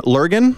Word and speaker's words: Lurgan: He was born in Lurgan, Northern Ireland. Did I pronounce that right? – You did.